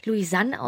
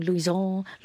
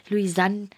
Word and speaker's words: Louisan: Ich wollte es ganz einfach machen und dachte, man wird es Louisanne aussprechen, aber seitdem gibt es immer wieder Verwirrung. Dem einen sagen Louison, Louisanne. Ähm also es gibt alle möglichen Formen, aber Louisanne ist für mich richtig.